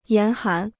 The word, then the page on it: 严寒: yán hán